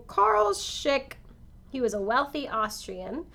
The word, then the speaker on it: Schick: When Vera was 19, she married a wealthy banker named Carl Schick. Schick. Schick. – Carl, what's how do you spell it? – S-C-H-I-C-K. Schick.